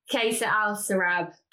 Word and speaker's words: Qasr Al Sarab: And I'd do three, four days out in the um, Anantara uh, I think I've pronounced this right. Is it Kasar Al Arab? Qasr Al Sarab.